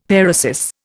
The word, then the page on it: paresis: Indian